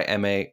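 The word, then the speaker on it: Emei: By the way, M.A. is spelled E-M-E-I. E-M-E-I.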